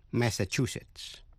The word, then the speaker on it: Massachusetts: Massachusetts, Massachusetts, Massachusetts, nhấn mạnh vào vần thứ ba.